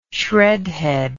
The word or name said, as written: shreadhead